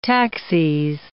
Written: taxis

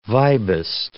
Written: vibist

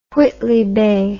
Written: Whitley Bay

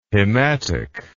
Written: hematic

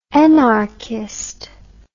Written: enarchist

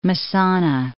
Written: Messana

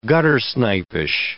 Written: guttersnipish

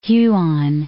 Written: Huon Gulf

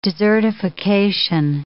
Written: desertification

